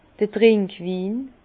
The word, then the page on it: d'r Trinkwin: Bas-Rhin; Herrlisheim